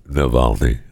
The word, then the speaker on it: Navalny: And just so we're clear, I know it's Navalny. Okay. I got it. It's Alexei Navalny. Just, I have fun saying his name wrong.